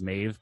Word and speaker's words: Meadhbh: You can see this in like the Irish name Maeve is a good example, how the old spelling is like, Jesus, M-E-A-D-H-B-H. And you think, how does that pronounce as Maeve? But like the modern, kind of more anglicized version is like M-A-E-V-E, Maeve, that way.